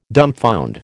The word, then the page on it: dumbfound: American English